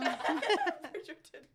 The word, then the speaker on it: Bridgerton: If it was the guy from Bridgetown. – Bridgerton. Not from Bridgetown.